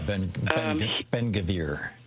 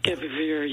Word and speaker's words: Ben-Gvir: And they brought up that political leader, the security minister, Itamar, hope I'm pronouncing this right, Ben-Gvir. – Yeah, Ben-Gvir. – Ben-Gvir, yeah. He was the guy who did the abuse in the last flotilla.